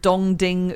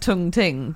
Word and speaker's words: Dong Ding: So if you get it at the right time, you get, for instance, something called Dong Ding Oolong tea, which actually I think it's pronounced Tung Ting, but I like the idea of changing the phrase ding dong to dong ding.